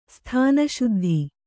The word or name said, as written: ಸಾಧನಸಿದ್ಧಿ